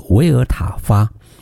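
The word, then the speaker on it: Vltava: 莫尔道是一个德国的发音，而捷克的发音呢叫做维尔塔发。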